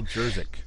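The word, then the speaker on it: Juszczyk: And he's a really interesting guy, and I keep thinking about the fullback on the 49ers whose name I always get wrong because it's like Jerzyk or something, but yeah. I can't pronounce his name. – Kyle Jerzyk. – Yes, Jerzyk, the guy they paid $1 million, and everybody said, why did they do that?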